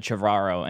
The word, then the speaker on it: Trevorrow: Unless it was a joke, but it was like an interview between him and Chris Pratt, and Chris Pratt said Trevorrow, and he said, "You finally got my name right for the first time."